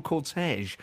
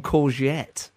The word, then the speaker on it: cortege: Lisa in Preston says, my lovely husband calls a funeral cortege a funeral courgette. Very different.